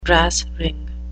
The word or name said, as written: brass ring